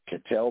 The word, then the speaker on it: Ketel: And Ketel Marte, or Kettle, depending on how you want to pronounce his name, is one of the best players in baseball that no one has ever heard of.